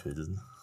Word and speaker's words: Pilsen: Ja, das schreiben sie vielleicht so hin, aber spricht man das so auch? – Man sagt Pilsen.